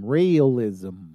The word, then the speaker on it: Raelism: It's spelled R-A-E-L-I-S-M. Raylism. – Raylism.